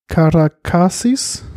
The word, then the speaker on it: Karakasis: Janis Karakasis, mhm.